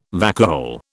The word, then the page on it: vacuole: British English